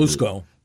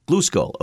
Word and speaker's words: Glusgol: Bluskull. – Bluskull. Okay. What's Melvin's story? – Yeah, G-L-U-S-G-O-L.